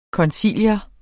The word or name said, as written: koncilium